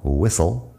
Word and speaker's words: whistle: Whistle es un sonido, whistle. Uh, whistle también tiene la H muda, por cierto, después de la W.